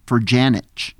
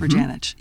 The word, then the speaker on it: Ferjanic: I can pronounce his last name. It is Ferjanic. – Okay, that's right. The I-C-H at the end. Mm-hmm. Ferjanic.